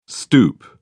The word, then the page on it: stoep: British English